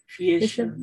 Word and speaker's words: Fleischer: Pese a ello, las coincidencias y confusiones saltan a primera vista si se le compara con Betty Boop, dibujo animado por Max Fisch, no estoy segura cómo se pronuncia ese apellido. Fleischer, ¿no? – para Paramount Pictures.